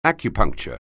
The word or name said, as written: acupuncture